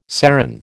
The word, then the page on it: serran: American English